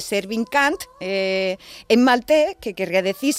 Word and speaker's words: Cant: Y se pronuncia igualico, idéntico que la palabra cant, que es, significa en inglés coño. Se escribe de distinta manera, pero suena exactamente suena igual.